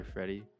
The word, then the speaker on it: Fredxy: Go by Freddy, spelled with an X.